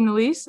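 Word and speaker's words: Nélisse: I might be wrong on the reason why, but I mean, I do think of the teenage characters, I think her and Sophie Nezel, I might be pronouncing Nalise? That wrong. The young Shauna. – Sophie Nalise, I think. – Oh, is it Nalise? Okay, thank you.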